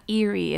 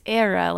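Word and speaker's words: Éire: And he's like, you've been saying it wrong. It's not Erie, it's Era, like the shores of Era in Ireland.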